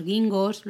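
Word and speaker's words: ginkgos: y poder fotografiarlos con esas hojas rojas de los arces o amarillas de los guingos, ¿lo he dicho bien? ¿Guingos? – Sí eh, es súper bonito y además es eso, es un mega parque que lo tenéis en el centro de, de Kioto.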